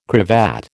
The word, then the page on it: cravat: American English